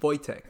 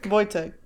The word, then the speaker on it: Wojtek: Wojtek. – Wojtek. In my head, I was like, oh, V-O-L-T, whatever.